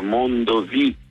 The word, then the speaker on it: Mondovì: prendo un altro treno e arrivo a Mondovì, quindi tutti i posti con l'accento sulla I.